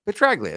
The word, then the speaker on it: Petraglia: Is it Petraglia or Petralia? Petraglia. It's Mike Petraglia.